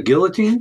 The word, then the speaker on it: guillotine: It's a good thing we are now because I lost my job at the Gallatine thing? – The guillotine? – Thank you. The guillotine.